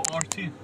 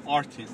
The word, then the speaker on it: Artin: Oh, Artin. It's like Artin? – Like Martin without M. Artin.